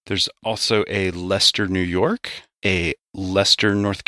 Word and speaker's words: Leicester: L-E-I-C-E-S-T-E-R, and if so, how does it get pronounced? – There is one in Massachusetts, and it's pronounced Leicester. – Wow, they got it right.